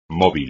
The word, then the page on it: móvil: Spanish